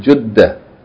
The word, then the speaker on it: Jeddah: Sometimes they say Jeddah, and some people say Jeddah, but the more proper, most and correct way of pronouncing this word is Jeddah.